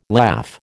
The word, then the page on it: laugh: American English